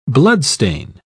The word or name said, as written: bloodstain